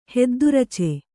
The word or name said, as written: ಹೆದ್ದುರಚೆ